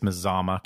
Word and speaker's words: Mazama: Well, and that episode was a campground review, and I'm saying this correctly, it's Mazama Campground.